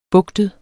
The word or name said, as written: bugtet